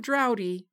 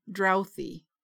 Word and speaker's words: drouthy: Not droughty, drouthy.